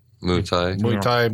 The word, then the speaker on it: Muay Thai: He has a front business for his drug selling, which is like a Muay Thai. Am I saying that right? – Muay Thai boxing establishment.